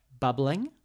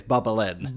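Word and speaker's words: Bubblin': Bubbling? – No. Pronounce it correctly, it's Bubbling. – Bubbling. – There ain't no G on the end.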